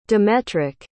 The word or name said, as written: demetric